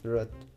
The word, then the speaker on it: Roth: Esta película estaba dirigida por Laia Ruth, ¿Ruth o Rot? – ¿Cómo se pronuncia? Rot. – Rot.